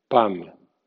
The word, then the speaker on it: palm: ALM suena AM o AM. Por ejemplo, PALM suena PAM o PAM sin L.